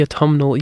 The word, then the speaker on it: autumnal: I like the fact that autumn, you don't pronounce the N until you say the autumnal with the N. It's not autumnal. – Yeah. – It's autumnal.